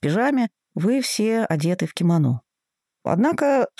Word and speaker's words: кимоно: Единственное, что, например, в русском языке ударение сместилось к последнему слогу, и мы говорим «кимоно», в то время как японцы произносят это слово «кимоно».